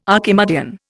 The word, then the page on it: Archimedean: Indian